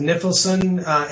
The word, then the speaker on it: Nifilson: I usually pronounce it Nifilson. It comes from Niflheim, which is a Norse myth. It basically means the land of mist. Nifilson would be the son of mist, right?